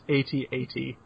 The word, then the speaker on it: AT-AT: I believe that is pronounced AT-AT.